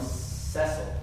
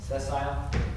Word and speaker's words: sessile: or it was most likely Ganoderma sessile. Sessile? Anybody know how to pronounce that? – Sessile. – Sessile? Okay, we'll go with that.